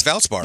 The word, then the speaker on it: Valspar: You know, right after the Players' Championship was here, the golfers had the Valspar. Is that how you say it? – Yes, Valspar.